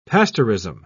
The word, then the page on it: pasteurism: British English